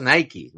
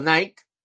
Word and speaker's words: Nike: Yo creo que, de hecho, se debe pronunciar Nike, ¿no? Eh, creo que sí, creo que sí. Pero aquí en España, como toda la vida ha sido Nike, en la, en la película dicen Nike.